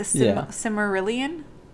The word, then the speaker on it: Silmarillion: I still don't know how to say it. The Silmarillion? – Yeah.